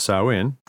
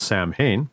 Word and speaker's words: Samhain: Stemming from the Celtic tradition of Samhain, even though it's spelled Samhain, the color black was chosen for Halloween to represent the death and darkness associated with the start of the winter season.